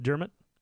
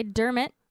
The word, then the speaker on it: Dermot: How do you say his name? Dermot Derm- Der- Dermot? – Isn't it not just Dermot? – Okay, Dermot.